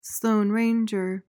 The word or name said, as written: Sloane Ranger